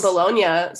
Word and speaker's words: Bologna: Family's from Bologna, so which is even more fun because it's just bologna. – That's bologna, yeah. – They still don't pronounce the G over there either, huh? – Mm-mm.